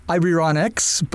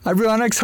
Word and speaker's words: Ibarionex: My name is pronounced Ibarionex, so it's Ibarionex.